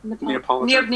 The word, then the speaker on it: Neapolitan: so I would have a good Napoleon or how do I say it? – Neapolitan. – Neapolitan pizza.